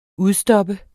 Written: UDSTOPPENDE

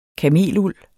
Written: kameluld

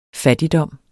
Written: fattigdom

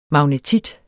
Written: magnetit

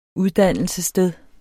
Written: uddannelsessted

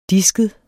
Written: disket

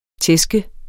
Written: tæske

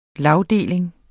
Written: lagdeling

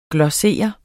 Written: glossere

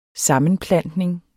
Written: sammenplantning

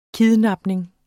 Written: KIDNAPNING

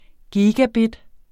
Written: gigabit

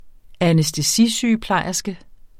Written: anæstesisygeplejerske

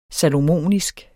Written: salomonisk

